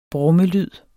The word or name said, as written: brummelyd